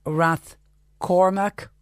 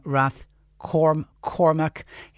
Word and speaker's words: Rathcormac: Would you please try for the new year, this is John to me, and pronounce Rath Cormac, Cormac with a C and not with a G, as in the townland near your hometown in Tipperary. And that's signed John, not from Rath Cormac.